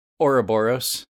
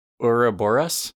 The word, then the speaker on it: Ouroboros: I saw Ouroboros, like Ouroboros, which is just changing a couple of vowels in a weird way that I wouldn't expect.